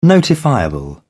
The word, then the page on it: notifiable: British English